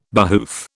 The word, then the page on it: behoof: British English